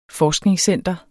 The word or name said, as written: FORSKNINGSCENTER